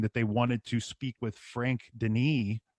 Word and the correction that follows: Dienes: or Denis, if I'm saying his name right, D-I-E-N-E-S. – Dienis.